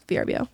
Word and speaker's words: VRBO: VRBO. – It's like Airbnb. – Verbo. – Oh, Verbo. I gotcha. – It's not Verbo. It drives me insane. People call it that. It's VRBO.